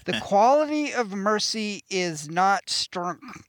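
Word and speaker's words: The Quality of Mercy Is Not Strnen: An album called, and it's going to sound like I'm not pronouncing this right, but I am. The Quality of Mercy is Not Stern.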